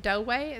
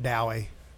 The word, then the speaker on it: Douay: I think I've heard them pronounce that Douay. – Douay.